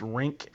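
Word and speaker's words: Rienk: I know that Nebraska, one of their key players, I believe his first name is pronounced Rink and his last name Mast.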